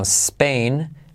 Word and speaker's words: Spain: Spain, de nuevo semiclave S, porque no se dice e, Spain, sino Spain, es, y como E, not en, T como R suave, naren, y como E, not in, se convierte en naren.